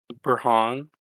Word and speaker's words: Burhan: How do you say it? – Burhan. But like... – Oh, there's a gap? – Yeah. It's like, it's two syllables.